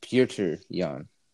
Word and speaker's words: Pyotr: He's from whatever country that flag is, so his name is pronounced Pyotr Yan. No, Pyotr. – Perta.